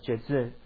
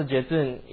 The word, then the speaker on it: Jetsun: So the word venerable in Tibetan is pronounced as Jezun. So Jezun, you can spell as J-E-T-S-U-N, Jezun.